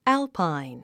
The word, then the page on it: alpine: ǽlpain; アゥパァイン